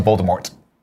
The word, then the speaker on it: Voldemort: Point of clarification: Ignacio Rojas in the chat says J.K. Rowling did say you don't pronounce the T. – Yeah. – Well, she better tell that to Emma Watson, okay, who in 7-8 movies keeps calling him Voldemort.